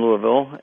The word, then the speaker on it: Louisville: Actually, you get to Louisville. You have to say it correctly. Louisville.